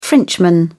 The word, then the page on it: frenchman: British English